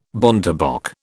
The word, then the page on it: bontebok: British English